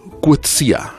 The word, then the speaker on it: Coetzee: Nosotros lo pronunciamos Coetzee o Coetzee, o lo españolizamos, pero su editor, que era también el mío, Claudio López Lamadrid, que falleció hace unos meses, su editor en España, lo pronunciaba Coetzee. – Uy, Coetzee. Qué bonito. – Coetzee. Entonces yo aprendí de él, Coetzee. Y supongo que sí, que lo dirá bien, y será lo más parecido a cómo se pronuncia en afrikaans.